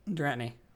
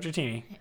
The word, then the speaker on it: Dratini: Dratney. What do you think about Dratney? I promise that's not how I pronounce it, by the way. I'm saying that as a joke, Dratini.